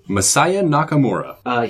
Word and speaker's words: Masaya Nakamura: Masaya Nakamura.